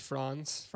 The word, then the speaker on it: Franz: I believe it's pronounced Franz. – Franz. – German.